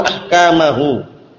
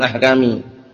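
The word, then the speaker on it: ahkama (أحكامه): Perhatikan di sini, bimisli hadzal bayani al-wadihi yubayyinullahu ayatihi wa ahkamahu. Ayatihi itu mansub di situ ya, maful bih, tanda nasabnya dengan kasrah karena jamak muannats salim. Ahkamahu ma'tuf, ahkama bacanya, bukan ahkami.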